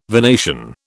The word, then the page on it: venation: British English